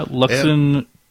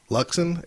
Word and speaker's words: Luxen: Yeah, Luxen. – Luxen? L-U. – Tuinter. – Was it L-U? – X-E-N. Then last name's T-U-E-N-T-I-R.